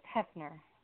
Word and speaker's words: Heafner: No, actually, it's Hefner. – Hefner, thank you. Okay, thank you. Is the A in there, H-E-A, F-N-E-R? – There is an A, but that A is silent, yes.